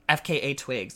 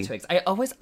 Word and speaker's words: FKA Twigs: FKA Twigs. Did I say that right? – Mm-hmm. Absolutely. – FKA Twigs.